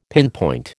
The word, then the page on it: pinpoint: American English